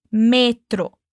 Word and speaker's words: metro: Muito cuidado com a pronúncia de metrô, que em espanhol a gente dá ênfase no me, metro.